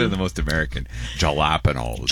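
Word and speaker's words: jalapeños: I picked like two or three jalapenos the other day for the very first harvest, but it's not enough. It's not quite enough to pickle yet. – I think you said it wrong. It's jalapenos.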